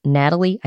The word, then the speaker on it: Gnatalie: Alyssa Bell, who's directing the fieldwork, said that the skeleton is known as Gnatalie. I think that's how it's pronounced. It's spelled with a G, G N A T A L I E.